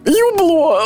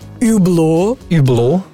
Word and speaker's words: Hublot: Юбло.